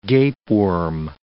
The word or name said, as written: gapeworm